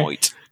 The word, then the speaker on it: Benoit: I used to call him Chris Benoit. – Same here. Benoit. When I first moved to the US, I couldn't pronounce his name, so I said Chris Benoit. And I did get make fun of it.